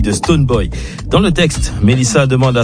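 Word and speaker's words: Stonebwoy: On va écouter la révélation Melissa et le patron de l'Afro-Dance Hall ghanéen Stoneboy sur le titre Show Me. – Absolument Lisa, et bravo pour la prononciation de Stoneboy.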